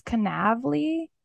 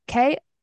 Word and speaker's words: Konavle: It's, I think this is Kanavli. It's Konavle.